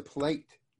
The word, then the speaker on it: plait: To you then is, have you experimented with the plate or plat? Have you heard about or used, or have you heard about that? – If you mean not, plank? So, the plate, P-L-A-I-T is how it's spelled.